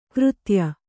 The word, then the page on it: ಕೃತ್ಯ: Kannada